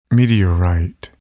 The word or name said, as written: meteorite